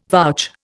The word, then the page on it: vouch: Indian